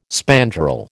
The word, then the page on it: spandrel: American English